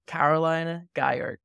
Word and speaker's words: Carolin Geier: Today, I've got with me Caroline Geier, co-founder at Mintbase.